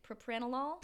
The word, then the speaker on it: propranolol: These researchers in Netherlands were screwing around with this drug called propranolol. How do you pronounce that? Propranolol? – Propranolol. – There's a drug called propranolol. – Propranolol. Laughing out loud.